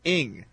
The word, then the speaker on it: Ng: It is pronounced like this, Ing. He says it's I-N-G.